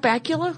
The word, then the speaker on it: Bakula: Did you ever watch Scott Bakula, whose name rhymes with Dracula? – But Cracula. – Oh. – Okay. So a lot of people don't know it, but it does.